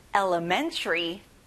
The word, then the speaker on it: elementary: 초등, elementary. 자, man에 강세를 넣어보세요. Elementary.